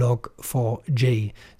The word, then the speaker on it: Log4j: Sie wird geschrieben Log4J und ausgesprochen Log4J. Sie ist äußerst beliebt und deswegen auch in einer großen, unbekannten Anzahl von Programmen und Systemen verbaut worden.